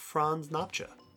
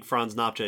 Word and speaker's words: Nopcsa: I have reason to think Franz Nopcsa is how you actually say the name.